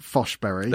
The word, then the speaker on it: Forsberg: winger Emil Forsberg, which is apparently pronounced Foshberry.